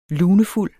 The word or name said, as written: lunefuld